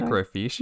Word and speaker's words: microfiche: Microfiche.